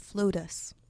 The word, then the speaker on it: Floatus: So tell us a little bit about Floats and how you got into the unique business that you are in. – Absolutely. It's actually pronounced Floatus, like Lotus with an F.